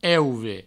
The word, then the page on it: euve: Provençal